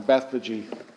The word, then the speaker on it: Bethphage: Now, when they drew near to Jerusalem and came to Bethphage, and I looked this up, and I think there's as many ways to pronounce this. I like that one, Bethphage.